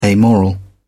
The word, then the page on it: amoral: British English